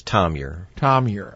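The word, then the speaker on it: Thomure: That's origin of French origin, correct? – That's correct. – Yeah. Say it correctly for all of us. – The way we've always said it is Tom Muir.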